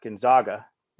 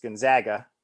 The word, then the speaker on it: Gonzaga: All right, Captain Insano shows no mercy, checks in with Gonzaga with the proper pronunciation, not Gonzaga. It's Gonzaga, I believe.